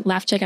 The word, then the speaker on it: Lewczyk: Personally, if you want to follow me on LinkedIn, Megan Lefczyk, I'm going to spell it. It's hard. L-E-W-C-Z-Y-K.